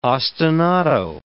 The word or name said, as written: ostinato